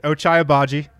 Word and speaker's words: Ochai Agbaji: Ochai Agbaji.